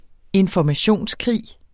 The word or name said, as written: informationskrig